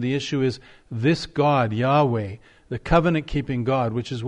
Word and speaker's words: Yahweh: Yahweh is our God. Yahweh is the name that God revealed himself to Moses and to the children of Israel. Sometimes it's pronounced, I think, incorrectly as Jehovah.